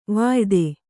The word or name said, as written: ವಾಯಿದೆ